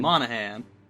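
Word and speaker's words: Monaghan: Did a horribly mispronounce his name. – Monahan. – There we go, Monahan.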